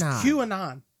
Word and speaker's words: QAnon: It's QAnon.